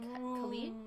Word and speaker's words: Khalid: It's a single by Kane Brown, Swae Lee, and Khalid.